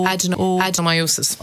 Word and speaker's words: adenomyosis: Sorry, just a question, a late question has come in, and it's about, now you'll have to pronounce this for me, A-D-E-N-O-M-Y-O... – Adenomyosis. – Okay. This listener says, will you ask about adenomyosis? I had an MRI to check for endometriosis, but I was told I had adenomyosis.